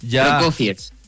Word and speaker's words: Prokofiev: Me cuesta pronunciar. Procafié. ¿Algo por ahí? – ¿está bien dicho? – Soy bailarina, sí. Prokofiev. – ¿Ve? Prokofiev.